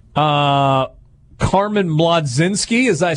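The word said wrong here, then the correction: Mlodzinski: Oh, Majinski. Um, Carmen, it, it is spelled Mlod because that's kind of a hard sound to make. M L M L O D Z I N S K I Majinski.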